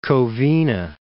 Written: West Covina

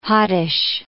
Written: hottish

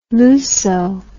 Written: Luso

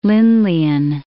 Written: Lindleyan

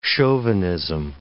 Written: chauvinism

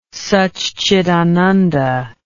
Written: saccidananda

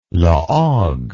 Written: La Hogue

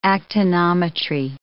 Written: actinometry

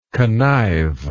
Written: connivingly

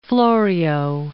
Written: Florio

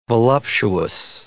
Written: voluptuous

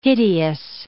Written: hideous

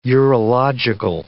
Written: urological

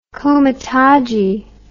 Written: comitadji

